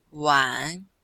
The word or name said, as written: wan3